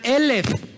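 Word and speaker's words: Elef (אלף): En hebreo igual se escribe Aleph. Solamente que se pronuncia Elef. Pero Elef tiene que ver con mil.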